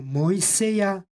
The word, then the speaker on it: Mojseja: U obliku imena Mojsej, Mojseja, izgovorili smo ižicu kao j. Rekli smo, kada u srpskom ekvivalentu imamo izgovor j, tako onda i u crkvenoslovenskom ižici dajemo glas j. Stoga ovdje izgovaramo Mojseja prema ekvivalentu u srpskom jeziku. Posljednje az, slovo az, ima glasovnu vrednost ja, jer je ovdje reč o stranom imenu i az posle vokala izgovara se ja.